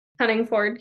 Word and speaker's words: Honigford: Honigford, yeah.